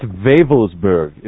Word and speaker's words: Wewelsburg: It's Wewelsburg, if you want to give the German pronunciation. W's are pronounced as V's in German.